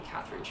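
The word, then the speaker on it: Kathryn: But yes, there's the Instagram and there's also my website, which is, again, just my name, Catherine Shriver.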